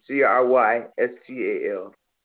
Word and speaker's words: Crystal: C R Y S T A L.